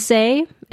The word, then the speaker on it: Issei: Is it Issei? Is that how you pronounce his name? – I think it's Issei.